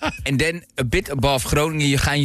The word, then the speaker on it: Groningen: What was this city pronounced? – Yeah, I still can't. I'm trying. – Groningen. – Groningen. – Groningen. – Groningen. Groningen. Groningen. Yes.